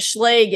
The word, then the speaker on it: Schlage: We did confirm that Schlage is the correct pronunciation for those who might not know.